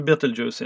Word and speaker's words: Betelgeuse: Betelgeuse. Eh, amerikaner uttalar Betelgeuse som filmen Beetlejuice. Eh, jag är lite osäker på hur man ska uttala det, men Beetlejuice är nog rätt egentligen. Någonstans